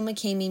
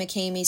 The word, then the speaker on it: McCamy: Well, everything is under Rachel McCamy Music, and that's spelled R-A-C-H-E-L. And then my last name McCamy spelled M-C-C-A-M-Y.